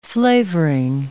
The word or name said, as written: flavoring